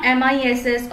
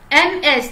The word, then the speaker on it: Ms: Now why, uh, why I'm writing here MS not MISS or MRS. The reason is uh, MS that is called Ms. The pronunciation is called Ms. It's not Miss.